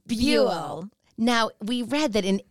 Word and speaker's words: Buell: What do you think of that middle name, Buell? – Buell. – B-U-E-L. – Buell. – Rhymes with fuel.